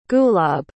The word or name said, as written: gulab